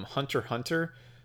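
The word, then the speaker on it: Hunter × Hunter: characters from Hunter Hunter, or which is apparently how you're supposed to pronounce the show Hunter X Hunter, um, because there's an X in there that uh, if you pronounce it, you're you're not a true fan of anime.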